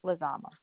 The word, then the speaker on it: Lizama: Lizama.